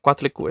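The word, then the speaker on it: Coatlicue: So, Cuatlicue? Yeah, Cuatlicue. – That sounds right.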